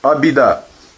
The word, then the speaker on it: абхидея: И мы говорили, самбанда – это абхидея. Абидея – беда. Беда. Что такое беда? Абида. Абхи – ха не читается. Абида получается. Абида.